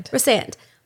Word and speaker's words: Rhysand: How do you say Rhysand? – Rhysand.